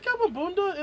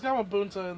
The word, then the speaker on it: Gamabunta: Is it Gamabunda in the dubs? – It's Gamabunda, yeah. – It's Gamabunta in the subs. – Probably saying it wrong. – Oh, okay. Cool. Yeah. Well, it's a T in the subs.